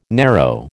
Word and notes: narrow: American English

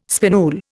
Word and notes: spinule: Indian

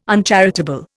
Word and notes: uncharitable: Indian

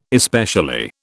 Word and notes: especially: British English

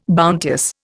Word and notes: bounteous: Indian

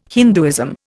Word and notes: Hinduism: Indian

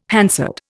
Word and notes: Hansard: Indian

